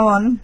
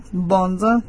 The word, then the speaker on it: Bond: Se llama, tiene mi nombre, Cristina Bond, ¿sí? B larga O-N-D, Bond, como James Bond, así se escribe.